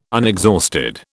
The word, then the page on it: unexhausted: British English